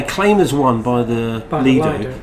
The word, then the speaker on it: Lido: It's pronounced Lido. And so I say Lido. Nath picks me up on it as well. But then, to be fair to Nathan, he also said, yeah, he's been there. I haven't even got the excuse to be. I've never even been to it. But Lido is the Italian pronunciation, and that's where the word comes from.